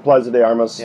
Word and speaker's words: Plaza de Armas: My hotel was right across from Plaza de Armas, is I think the pronunciation of that. – Yep, Plaza de Armas.